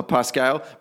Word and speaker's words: Parscale: Parscale? How do you pronounce that? – Parscale.